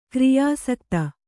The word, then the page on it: ಕ್ರಿಯಾಸಕ್ತೆ: kriyāsakti